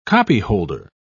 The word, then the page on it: copyholder: British English